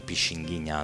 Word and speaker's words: Pixinguinha: Comunque questo è un album del 2000, si chiama Pixinguinha, The Bolso Pixinguinha, Pixinguinha, anzi la pronuncia esatta è così,